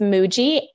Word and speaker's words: Muji: It's Muji, M-U-J-I.